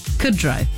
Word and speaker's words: Kudrow: How do you say it? – Lisa Kudrow. – Kudrow? – Yeah.